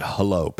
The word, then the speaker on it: Hullo: It's our good friends at Hullo Pillow. It's H-U-L-L-O, but they pronounce it Hullo.